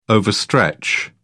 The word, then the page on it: overstretch: British English